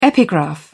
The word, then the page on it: epigraph: British English